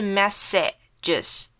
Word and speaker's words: messages: 好，messages 它的拼法虽然中间是一个 a，可是我们把它念成 e 的音。好像是 messages 这样，messages，OK。